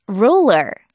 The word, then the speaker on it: ruler: 자는 영어로 ruler. Ruler. 발음이 조금 어려워요. 그렇죠? R 발음하고 L 발음. 그리고 또 끝에 R로 끝나기 때문에 연습 많이 해야 되는데 ruler.